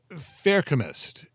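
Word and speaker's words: Feruchemist: He's also what's known as a Faircomist, if I'm saying that right. It's kind of like a combination of ferrous, as in ferrous metals, and alchemist, as in alchemy. So, F-E-R-U, chemist.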